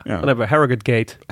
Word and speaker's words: Harrogate: En het WK is dus in Harrogate. Niet in Harrogate. Harrogate. Nee, Harrogate. Zegt Willem. – Harrogate. – Ja, heel goed.